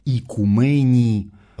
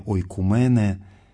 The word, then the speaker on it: ойкумене: Вселенная – это перевод греческого слова «икумени», слово, которое также можно прочитать как «ойкумене». Это древнее античное произношение «ойкумене».